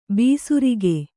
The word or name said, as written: ಬೇಸರಿಕೆ